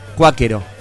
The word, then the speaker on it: cuáquero: Y la palabra es esdrújula. Cuáquero.